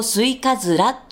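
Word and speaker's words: 忍冬: まあ、さて、私ですね、まあ、新曲が発売になりまして、ふるさとスイカズラという歌です。スイカズラっていうのが漢字なんですけれども、忍ぶ冬と書いてスイカズラと読みます。まあ、忍道とも読むんですけれども、それでも間違いではないんですけれども、スイカズラ、ふるさとスイカズラという歌です。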